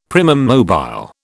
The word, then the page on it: primum mobile: British English